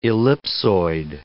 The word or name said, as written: ellipsoid